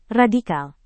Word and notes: radikal: Indonesian

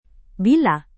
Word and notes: villa: Indonesian

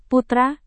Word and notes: putra: Indonesian

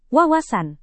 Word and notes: wawasan: Indonesian

